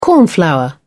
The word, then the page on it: cornflower: British English